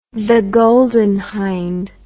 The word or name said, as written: golden hinde the